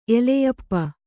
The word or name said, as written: ಎಲೆಯಪ್ಪ